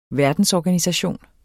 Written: verdensorganisation

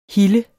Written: hilde

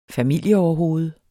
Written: familieoverhoved

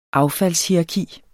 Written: affaldshierarki

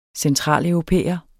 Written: centraleuropæer

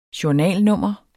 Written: journalnummer

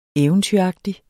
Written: eventyragtig

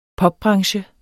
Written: popbranche

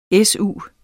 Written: s.u.

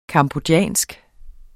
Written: cambodjansk

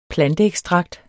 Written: planteekstrakt

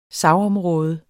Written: sagsområde

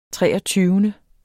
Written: treogtyvende

